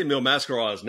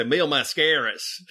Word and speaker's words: Mil Máscaras: Jerry Jarrett and I pronounced I say Mil Máscaras now because that's the way I've heard it pronounced, I think, on some of the Houston tapes. But for years, I used to say Mil Máscaras.